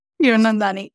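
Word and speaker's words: Hiranandani: Hiradani. Is that how I pronounce it? – Hiradani. Yep.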